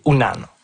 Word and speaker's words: Unan: Puis le vrai mot Innu, c'est Unan, ou parfois prononcé Wanan.